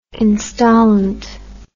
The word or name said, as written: installant